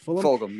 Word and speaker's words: Fulgham: Fulgham, yeah.